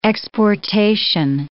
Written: exportation

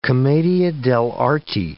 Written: commedia dell'arte

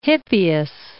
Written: Hippias

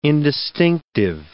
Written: indistinctive

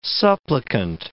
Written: supplicant

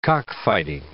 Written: cockfighting

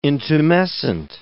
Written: intumescent

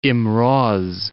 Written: Imroz

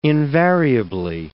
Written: invariably

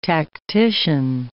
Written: tactician

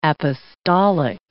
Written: apostolic

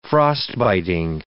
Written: frostbiting

